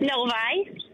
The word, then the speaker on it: Novi: Is it Novi, Michigan? Have I described and pronounced your place accurately? – It's Novi.